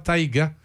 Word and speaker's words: Taiga: Fabricant de motoneige et de moto marine électrique moteur Taiga. Je sais pas si c'est comme ça qu'on le prononce. Je pense que oui. T-A-I-G-A. – Oui, euh, on dit ça comme ça.